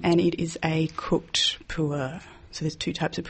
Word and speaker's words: pu'er: This is a pu'er tea from Yunnan in southern China. – How do we spell that? – P-U-E-R.